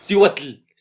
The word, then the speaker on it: Cihuatl: Cihuatl. Cihuatl. Cihuatanejo. No, el, tl no se dice, se dice como L nada más, la T no se pronuncia.